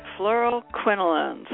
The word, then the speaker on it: Fluoroquinolones: Fluoroquinolones.